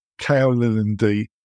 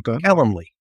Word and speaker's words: Calendly: Kaolinde. I don't think I've done too bad this time, but I regularly mispronounce it. How would you pronounce it? Because I struggle. – Calendly.